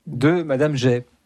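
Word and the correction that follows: Jaÿ: Ou Madame Jaï, pardon.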